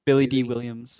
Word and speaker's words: Billy Dee Williams: Billy D. Williams.